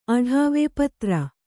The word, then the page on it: ಅಡಾವೆ: aḍāve